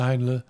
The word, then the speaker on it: Heinle: Amelia Henley. – Amelia Heinle. Heinle, I pronounce it the German way.